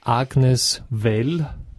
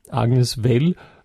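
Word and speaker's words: Agnes Vell: Das Lied war von der Band 3, das konnte ich vorher schon sagen, aber die Künstlerin, die dahinter steht, ist Agnes Well, mit V übrigens geschrieben, aber mit W ausgesprochen. Agnes Well,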